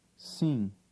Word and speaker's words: sim: The final M is silent, but signals a nasal sound. Try word 7. – Sim.